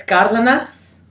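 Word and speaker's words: Cárdenas: So it's Cardenas, but it's Cardenas is how you're supposed to say it. – Cardenas. – Right, so it's Spanish.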